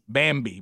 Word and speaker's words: Bambee: All right, so Bubba, I want the audience to write down B-A-M-B-E-E, Bambi, but it's spelled with two E's at the end.